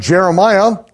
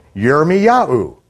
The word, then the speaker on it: Yirmiyahu: Instead of Jeremiah, we'll pronounce it Yirmiyahu, with the emphasis on Yah.